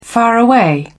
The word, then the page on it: faraway: British English